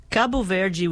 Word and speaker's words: Cabo Verde: I mean, Cabo Verde would be the proper way to say it in Portuguese. – Yeah. – And I think, you know, with a slight accent difference, that would be the correct way to say it in Crioulo also.